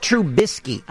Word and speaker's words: Trubisky: They can't pronounce his last name. Mitch Trubisky. The biscuit. It's Mitch Trubisky.